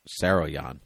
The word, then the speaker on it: Saroyan: the Human Comedy, directed by Clarence Brown, written by William Saroyan. Saruman? Saroyan, yes, oh.